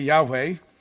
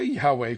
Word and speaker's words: Yahweh: Leviticus 23, 1 and 2, Yahweh, whenever you see the Lord, in capital L-O-R-D, that's been changed. The Hebrew word is Y-H-V-H, and the V's were pronounced more like a W back then, so that's why I say Yahweh.